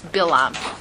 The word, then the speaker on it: Balaam: Bilam arose, and even though it says Balaam, it's pronounced Bilam.